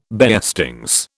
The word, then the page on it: beestings: British English